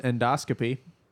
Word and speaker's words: endoscopy: It's pronounced endoscopy. Not endoscopy. Yeah.